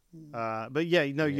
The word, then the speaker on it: Rijeka: how do you pronounce it, Rijeka? – Yeah, I think something like that. Rijeka.